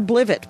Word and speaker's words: Blivet: It's at Ginger Blivet, by the way. B-L-I-V-E-T.